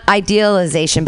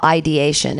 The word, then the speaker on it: ideation: Uh, and it's pronounced weird. It's not idealization, but it's suicidal ideation,